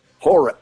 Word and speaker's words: Hora: Hora. – Hora. H-O-R-A.